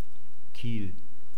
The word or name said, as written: kiel